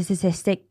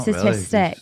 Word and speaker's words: statistic: And there is a statistic. It's a big word, that, isn't it? – It's not statistic. Really. Absolutely butchered it.